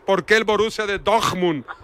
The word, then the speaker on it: Dortmund: Ah, lo puedo hablar también, pero hablo el deje de la cuenca del Ruhr y ahí se pronuncia Dortmund.